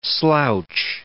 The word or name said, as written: slouch